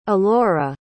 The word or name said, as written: alora